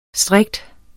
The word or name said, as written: strikte